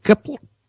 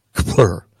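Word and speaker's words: Kpler: Kepler? It's K-P-L-E-R. Kepler.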